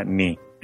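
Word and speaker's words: ni (二): Eh, y eso es así porque en Japón el, el 2 se pronuncia ni.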